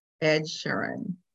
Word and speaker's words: Ed Sheeran: I love Ed Sheeran. Oh. Did I say that correctly? – Yeah. – Yes.